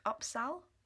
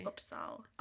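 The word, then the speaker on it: Upsahl: By, I think it's pronounced Upsal, but it's U-P-S-A-H-L. – Upsal, maybe. – Upsal.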